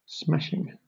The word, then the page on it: smashing: British English